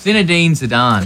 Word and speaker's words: Zinedine Zidane: And I knew that, which is fine, had Googled it, because how do you pronounce his name? – It's Zinedine Zidane.